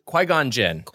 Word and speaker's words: Qui-Gon Jinn: Qui-Gon Jinn? – Qui-Gon Jinn.